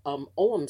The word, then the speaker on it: Ogham: and it's spelled O-G-H-A-M, but it's pronounced Ogham.